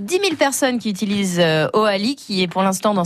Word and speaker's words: Hoali: Voilà, Oali qui s'écrit H-O-A-L-I.